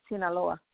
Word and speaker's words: Sinaloa: Yeah, Sinaloa.